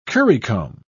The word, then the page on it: currycomb: British English